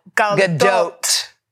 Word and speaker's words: Gadot: Okay, how about Gal Gadot. – I like it. – And by the way, that's how you pronounce her name. – Gadot. – Like goat.